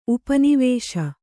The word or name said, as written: ಉಪನಿವೇಶ